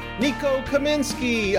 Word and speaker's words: Nico Kamenzky: Today we have Nico Kamenzky of Morningfame.